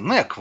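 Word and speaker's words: Knack: его перевели как Кнак, поэтому буду говорить официальное название. Но ну, Кнак вроде не читается, Нек вроде.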